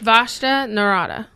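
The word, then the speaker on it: Vashta Nerada: Vashta Narada.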